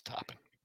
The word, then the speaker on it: Toppin: We had Topin versus Toppin. I'm pretty sure it's Toppin. – Sure it's Toppin. It's Toppin.